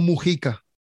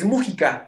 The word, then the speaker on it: Mújica: Oye, tengo una duda, ¿es Mújica o Mujica? – Es Mújica. – Mújica, ¿verdad? Con acento en la U, claro.